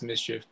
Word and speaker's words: MSCHF: I think it's pronounced Mischief, but whatever.